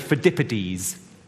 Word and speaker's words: Pheidippides: I think it's pronounced Pheidippides. Turn to the person next to you and say Pheidippides.